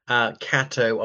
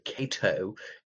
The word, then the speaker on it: Catto: Catto, I once mispronounced it Cato in my first soiree with him, who was a fixture at Oriel College for over four decades.